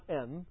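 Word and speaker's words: en (ἐν): The Greek word there is not "en," which would be epsilon nu, pronounced "en."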